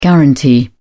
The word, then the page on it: guaranty: British English